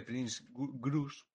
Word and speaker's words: Prince Grus: Oye, eh, eh, me encanta el nombre de Prince Grus porque es en... como en japonés se pronuncia las Pringles.